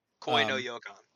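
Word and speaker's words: Koi no Yokan (恋の予感): And I've with the exception of the one I always pronounce wrong, the Noi Yokan one. – Koi no Yokan.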